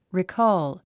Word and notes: recall: rikɔ́ːl; リィコーゥ

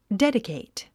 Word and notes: dedicate: dédikèit; デディケイト

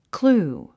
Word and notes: clue: klúː; クルゥ

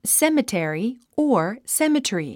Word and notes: cemetery: sémətèri; セメテリィ

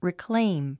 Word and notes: reclaim: rikléim; リクレイム

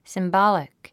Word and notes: symbolic: simbάlik; スィンボォリック

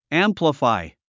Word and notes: amplify: ǽmpləfài; アンプラファイ

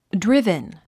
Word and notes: driven: drívən; ドリベン

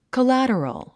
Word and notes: collateral: kəlǽtərəl; コラァトロォ

